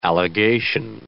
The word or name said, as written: allegation